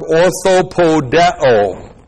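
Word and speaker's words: Orthopodeo (ὀρθοποδέω): The objective negative, ook, with the present active indicative of the verb orthopodeo. O-R-T-H-O-P-O-D-E long O. Orthopodeo.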